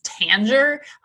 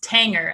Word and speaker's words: Tanger: I actually looked up the pronunciation just because in Minnesota, we used to call this Tanger outlets, but it is actually pronounced Tanger, as in rhymes with hanger, for Steve Hanger, one of the founders of Tanger Outlet Centers.